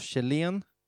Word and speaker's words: Kjellén: Vem var Rudolf Kjellén? Och vad eller uttalar jag fel? – Nej, det är korrekt.